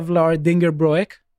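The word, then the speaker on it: Vlaardingerbroek: Now, let me say, Eva Vlaar Dingerbroek spoke... – Well pronounced. I can't pronounce her surname. – I've messed up pronunciation many times. I think I got it right this time.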